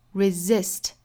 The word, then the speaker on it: resist: Resist, 第一个 s 读 z, 后面的 s 读 s, 所以是 resist, 再变成名词呢，这两个发音是不变的， resistance to concern, 单词内部含有字母 r, 卷舌 concern, resistance to concern, mastery of panic, 这里面两个字母 A 在美音里的发音就是一样的了。